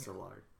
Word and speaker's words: Szilard: I feel like it's Szilard. Yeah, they don't pronounce the Z. It's Szilard.